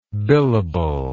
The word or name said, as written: billable